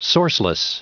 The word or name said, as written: sourceless